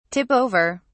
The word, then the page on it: tip over: British English